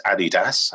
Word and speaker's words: Adidas: What was the as we say in Europe, and the way we the correct way to say it is Adidas, Adidas and everything else.